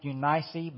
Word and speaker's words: Eunice: I think it's technically pronounced Eunice, but I'll forget to say Eunice, so I'm going to say Eunice.